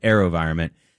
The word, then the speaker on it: AeroVironment: It's spelled A-E-R-O and then a capital V, Vironment, like environment, but AeroVironment.